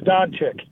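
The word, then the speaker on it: Dončić: Now, I know with Kokoskov's hire, a lot of people are talking about, I believe it's, I want to say it right, Luka Dencic out of Slovenia. – Luka Doncic. – Doncic.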